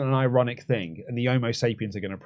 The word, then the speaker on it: Homo sapiens: Amos Sapiens. – Homo Sapiens. Come on, mate.